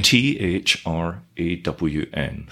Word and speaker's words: thrawn: Uh, spell that for me. T H R A W N. thrawn. Thrawn.